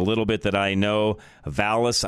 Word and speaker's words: Vallas: Paul Vallas. Make sure I say that correctly. Vallas, V-A-L-L-A-S, and Brandon Johnson.